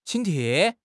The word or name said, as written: 请帖